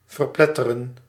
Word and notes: crush: IPA : /kɹʌʃ/

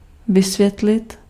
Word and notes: expliquer: [ɛks.pli.ke]; France

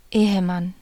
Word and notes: man: /mɑn/

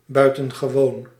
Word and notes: buitengewoon: /bʌj.tǝn.ɣǝ.ʋo:n/

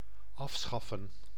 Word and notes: afschaffen: /ˈɑf.ˌsxɑ.fə(n)/

